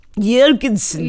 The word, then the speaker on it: Jorgensen: I want to say, now, Jorgensen. – Jorgensen. – Yeah, Christina Jorgensen. – Jorgensen. – She was an American. That's exactly how it's pronounced.